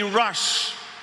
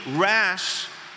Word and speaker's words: rash: Notice the pronunciation. It is not don't be rush. R-U. It is don't be rash in your judgment.